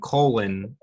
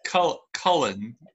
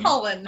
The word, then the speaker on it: Cullen: My favorite part of the summary is the fact that you insist on pronouncing their last name Colin. – Colon is something that helps you poop. – I know what a colon is. – And the Cullens are something that might be the product of your colon. – Cullen? – Yeah, Cullen, that's better.